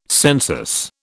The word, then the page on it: census: British English